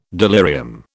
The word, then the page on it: delirium: British English